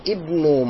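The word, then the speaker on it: Ibnu (ابن): Now there's nothing written before the word ibn, so that is why I am pronouncing the hamza. I'm saying Ibnu man anta, the son of who are you? Anabnu. Now yeah, I'm not pronouncing the hamza, I'm just saying anabnu. I'm not saying ana ibnu.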